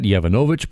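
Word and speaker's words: Yovanovitch: ambassador to Ukraine, Marie Yavankovych, I think I mispronounced that, Yavanovich, pardon me, says she was devastated when she learned that President Donald Trump wanted to remove her from her post.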